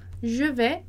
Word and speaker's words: vais: Je vais au cinéma. Tá? Então, lembrar que o aí vai ter som de é, então je vais, e a gente não vai pronunciar o S. Je vais au cinéma.